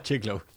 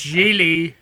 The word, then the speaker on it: Gigli: Oh, sorry. Not Jiglo. – Jiggly. Whatever it's called. – It's pronounced Gigli, everybody.